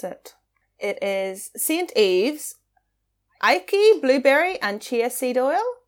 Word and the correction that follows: acai: Is it Aiki? A Chia? A Kia? A Sia? – Oh, Acai Berry.